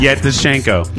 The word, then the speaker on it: Yevtushenko: Yevtushenko. – Yeah, let me help you with that. – Yevtushenko.